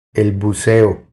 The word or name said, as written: El buceo